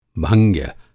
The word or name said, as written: भंग्य